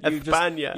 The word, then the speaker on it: España: España? Is that how they say it? – España.